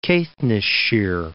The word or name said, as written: Caithness-shire